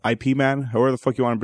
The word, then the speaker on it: Ip Man: It's Ip Man, I'm pretty sure. – I read it as Ip Man too. Are we correct? – You're correct.